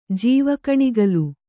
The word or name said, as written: ಜೀವಕಣಿಗಲು